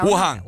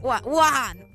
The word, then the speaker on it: Wuhan: Wuhan. Wuhan. Wuhan. – Tenés que con, ¿Me entendés? – Ah, perdón, Uno, dos, tres. Wuhan.